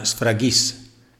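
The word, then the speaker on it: sfragis (σφραγίς): In greco si pronuncia sfragis ed è un vocabolo che significa sigillo, anello per sigillare.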